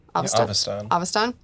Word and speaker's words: Avestan: He's skilled at portraying accents and mannerisms from all across, how's it pronounced, Avestan? – Avestan.